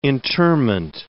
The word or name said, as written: interment